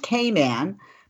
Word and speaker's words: Cayman: And speaking of welcome back, I just was in the Cayman Islands on the island of Grand. I'm saying it wrong. You know, it's not Cayman. Did you know it's Cayman? Like, hey, man.